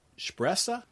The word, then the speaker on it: Spressa: And so Flora Cash are Cole and Spressa. – Oh, that was actually a really good pronunciation. – See, yeah. – Okay. – Yeah. – So you got the SH, the Spressa.